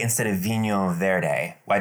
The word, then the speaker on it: vinho: But it's with an H. V-I-N-H-O. – That's not how you say it. You're spelling correctly. How do you say it? – Vino. – No. – Wino. – No. It's wiener. Thank you very much. Take a drink. It's vino. – It's what? – Vinho. – Vinho.